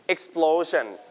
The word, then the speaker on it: explosion: It's called explosion. Listen to the audio. – Explosion. – One more time. – Explosion. – Your turn. Explosion. Stress on plo, explosion.